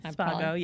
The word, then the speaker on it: Spago: So Ashley Rodriguez, she started her career working at Wolfgang Puck's restaurant, Spago. Am I saying that correctly? – Spago, yes.